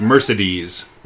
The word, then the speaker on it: Mercedes: I, by the uh, way, I heard uh, in a interview, it's it's Mercedes is how apparently she pronounces her name.